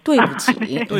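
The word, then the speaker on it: 뚜이부치 (对不起): 발음은 삼성해서 뚜이부치입니다. – 발음 너무 좋아요. 뚜이부치.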